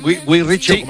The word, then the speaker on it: Guy Ritchie: Claro, es una inglesa de Guy Ritchie. ¿Lo he dicho bien? Guy Ritchie.